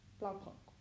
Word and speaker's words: Blaupunkt: So it was a, I won't say this right, Blaupunkt stereo that was already in the car. So it was like, great result. Speakers were rubbish. – I don't think you pronounced the T. It was Blaupunkt.